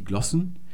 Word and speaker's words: Glosse: Wir haben einerseits die Glossen, da spricht man mit kurzem O aus, weil es eine geschlossene Silbe im Deutschen ist. Genauso wie die Glosse in der Zeitung, die man lesen kann. Stimmt also nicht, was euch euer Deutschlehrer wahrscheinlich gesagt hat, dass man Glosse sagen müsste.